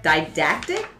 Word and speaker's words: didactic: Didactic? Is that, am I saying that right? – Yes.